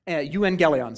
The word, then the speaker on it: euangelion: So in the Greek, the word is from the Greek word euangelion, okay? Euangelion, sorry. There's another syllable in there. Euangelion.